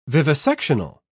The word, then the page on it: vivisectional: British English